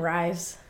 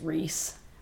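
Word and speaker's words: Rhys: I love how you call him Rise. I'm pretty sure it's Reese. – I think it's Rysand. – That's how you say it, but his nickname is Reese. – Why would his nickname be Reese if his name is Rysand? – Because it's R-H-Y-S.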